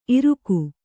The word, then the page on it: ಇರಿಕೆ: Kannada